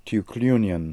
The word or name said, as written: Tookloonian